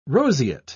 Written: roseate

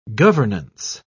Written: governance

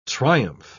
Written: triumph

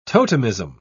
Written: totemism

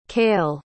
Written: cáel

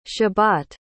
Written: shabbat

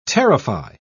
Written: terrify

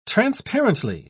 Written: transparently

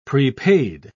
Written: prepaid

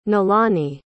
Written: nalani

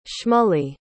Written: shmuley

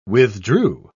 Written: withdrew

